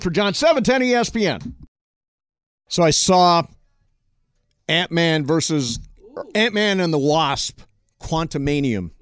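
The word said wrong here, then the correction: Quantumania: Quantum Mania.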